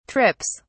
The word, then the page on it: trips: British English